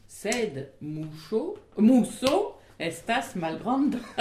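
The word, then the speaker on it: muŝo: M-U S-O, S ordinaire, alors que Musso, M-U-S, accent circonflexe O, S, chapeau O, pour prononcer CH, le son CH, Musso.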